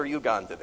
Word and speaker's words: Uganda: Do they pronounce it Uganda or Uganda there? Uganda. And he saw what was there in that country.